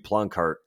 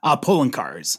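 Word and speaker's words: Poloncarz: and I believe Ploncaert said, is that how you pronounce his name? – Poloncaerts.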